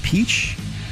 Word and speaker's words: Pesch: Last name is spelled P-E-S-C-H, but it's pronounced Peach.